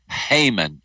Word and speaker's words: Haman: Character number two is named Haman, H-A-M-A-N. It rhymes with hangman because he is a man of death.